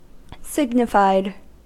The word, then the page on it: signified: American English